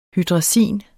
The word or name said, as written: hydrazin